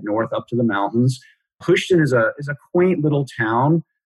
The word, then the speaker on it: Houston: Is the town Houston? Am I saying that right? – Well, I think it's pronounced Houston.